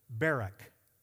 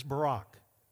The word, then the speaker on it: Barak: She got a man by the name of, well, you can pronounce his name one of two ways. I'm going to pronounce it Barak. The other way to pronounce it is Barak.